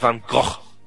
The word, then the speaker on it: Van Gogh: To Vincent Van Gogh's ear, or as it's supposedly meant to be pronounced, Vincent Van Gogh.